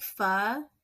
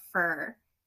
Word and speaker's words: pho: And then she would make pho, which I could never pronounce as a kid. I would always call it fur.